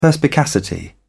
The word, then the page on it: perspicacity: British English